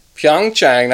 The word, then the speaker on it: Pyeongchang: Welcome to Pyeongchang. Still don't know if I'm saying it right. – Pyeongchang, that sounds right. Yep.